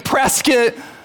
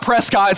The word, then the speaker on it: Prescott: And Prescott, it's not Prescott, it's Prescott, and it rhymes with brisket.